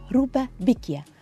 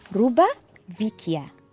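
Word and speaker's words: roba vecchia: روبا بيكيا في مصر يعني الحاجة القديمة، ونفس المعنى برضو بالطلياني، ولكنها بتتكتب ازاي وبتتقال ازاي؟ بتتقال روبا فيكيا، روبا فيكيا. والمصريين حرفوا البي الفي خلوها بي لسهولة النطق، وهذا هو المصري الرهيب العجيب الذي يبدع دائما.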